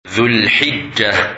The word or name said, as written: Dhul-Hijjah